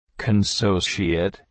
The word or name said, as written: Consociate